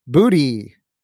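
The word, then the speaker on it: Boutte: I asked Kayshon Booty to clarify the pronunciation of his last name. He confirms it's Booty.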